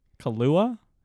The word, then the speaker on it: Kaluuya: It's got Lakeith Stanfield as William O'Neill, and I'm going to butcher his last name, Daniel Kaluuya.